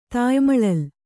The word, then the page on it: ತಾಯಿಮಳಲು: tāyi maḷalu